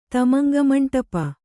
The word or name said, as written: ತಮಂಗಮಂಟಪ